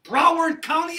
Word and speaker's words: Broward: You get a thought in your mind about planting a church in Broward County, is it? – Yeah. – Did I say it right? – Yeah.